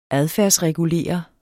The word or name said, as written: adfærdsregulere